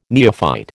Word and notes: neophyte: American English

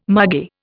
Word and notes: margay: Indian

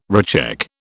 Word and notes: recheck: British English

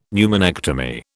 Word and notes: pneumonectomy: British English

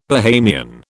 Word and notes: Bahamian: British English

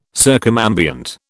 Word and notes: circumambient: British English

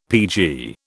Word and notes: PG: British English

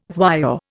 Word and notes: voyeur: Indian

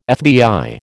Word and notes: FBI: American English

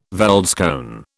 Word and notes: veldskoen: British English

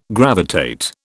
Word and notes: gravitate: British English

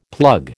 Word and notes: plug: American English

